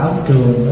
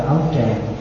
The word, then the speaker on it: áo trường: Đúng ra đọc là áo trường đó. Áo trường rất là dài đó. Nhưng mà tại vì bây giờ mình đọc đó, mình đọc áo tràng.